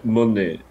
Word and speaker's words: Monáe: Correto. Monet. Ó, Clara Lima fala Monet. Se pronuncia Monet.